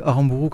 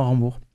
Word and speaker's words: Aramburu: Alors, originellement, on dit Aramburu comme vous faites bien de le faire, et ça a été francisé, c'est maintenant Aramburu. – Alors, on va le prononcer à la française.